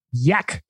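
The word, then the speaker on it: Jack: Nation Dan, your thoughts on Yak Campbell? I believe it's a soft J.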